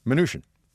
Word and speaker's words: Mnuchin: Mnuchin. – Mnuchin, like Mnuchin. Minutia. – Yes, sort of. Absolutely, yes.